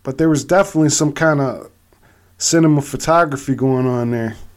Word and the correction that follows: cinematography: Did I say that wrong? Cinematography?